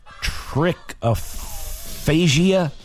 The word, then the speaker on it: trichophagia: Trichophagia. Okay. T-R-I-C-H-O-P-H-A-G-I-A.